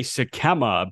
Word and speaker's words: Sikkema: TJ Sikkema, apparently, uh, that's what his pronunciation is on baseball reference. It was Sikkema.